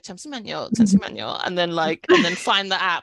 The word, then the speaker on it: 잠시만요: Was it 잠시만요? – 잠시만요. – Yeah. – 잠시만요.